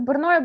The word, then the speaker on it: Брно: Правильно произносится? Брно, Брно. Брно, Брно, хорошо.